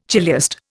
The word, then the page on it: chiliast: Indian